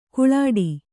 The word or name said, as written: ಗೋಳಿಡು